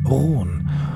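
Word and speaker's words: Rone: die haben das trotzdem französisch ausgesprochen, eben Rohn.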